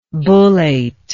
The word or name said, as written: bullate